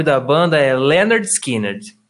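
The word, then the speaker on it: Lynyrd Skynyrd: Só que é, o nome, na moral mesmo, é Pronounced Leonard Skinner.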